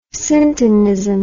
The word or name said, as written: syntonism